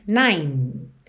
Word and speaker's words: nine: Canta o refrão de novo e depois, after 9 days, nine, não é nine, ok? Esse é final do número 9, não é pronunciado, então você diz nine, finaliza o som no N.